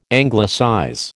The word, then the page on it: Anglicize: American English